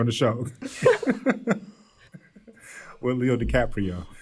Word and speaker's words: DiCaprio: DiCaprio? – did I pronounce his name correct? – You did.